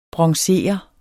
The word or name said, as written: bronzere